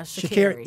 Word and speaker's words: Sha'Carri: And that would have been even if Shakiri. Is that how you pronounce it? – Yeah, Sha'Carri.